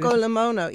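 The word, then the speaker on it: avgolemono: Stracciatella, as you mentioned, that Greek agolomono. Did I say that correctly? I never said it. – Avgolimono. Yeah, no, you've got it perfectly.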